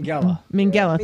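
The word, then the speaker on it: Minghella: I love The Social Network, but I have a hard time understanding why the Indian-American Harvard student Divya Narendra was played by Max Mingala? Is that how you say it? – Mingala, thank you.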